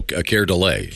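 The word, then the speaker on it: Keir Dullea: That's Kier Dulia? – No, Kier DeLay. – Oh, that's how you say it? – Yeah. Well, yeah. I like Kier Dulia. That's funny. – That's how it's spelled. – It is spelled Kier Dulia.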